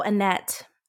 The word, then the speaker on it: Anethe: And so the idea is, or the thought is, that he quietly went in to try and I actually think her name, how do you say that? A-N-E-T-H-E, Aneth? – Oh, Annette. I think it's a net.